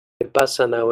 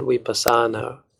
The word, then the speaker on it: vipassana: Vipassana, when many Westerners pronounce it vipassana, which is incorrect because the A is short, antepenultimate syllable, which is the bus, vipassana, for your information.